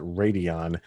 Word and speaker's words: Rodion: His goes by the name of, it's Radion, and his name is spelled R-O-D-I-O-N, but from all the pronunciations, I believe it's Radion Amirov.